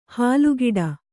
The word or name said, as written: ಹಲ್ಕಡಿ